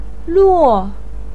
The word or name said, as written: luo4